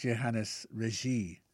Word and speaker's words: Johannes Regis: I pronounced him as if he was an Englishman. Johannes Regis, I called him. I guess it's Johannes Regis, Franco-Flemish composer.